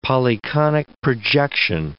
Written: polyconic projection

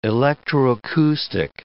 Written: electroacoustic

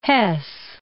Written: Hess

